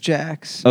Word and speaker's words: Jacques: Is it Jax or Jaquez? – It's Jax. – Okay. It's pronounced Jax? – Yeah, it's a French name that my family has made.